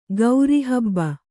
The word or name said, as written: ಕಾರಹಬ್ಬ